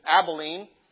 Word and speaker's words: Abilene: Now, of course, in Texas, we always said, I lived in Abilene. The Bible way of saying it is Abilene.